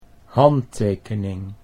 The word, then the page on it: handtekening: Dutch